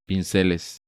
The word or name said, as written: Pinceles